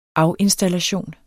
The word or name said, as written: afinstallation